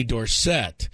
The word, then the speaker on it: Dorsett: When he first started his career, he was Tony Dorsett. – Really? – Yes. And then he said, no. You guys are all pronouncing it wrong. Tony Dorsett.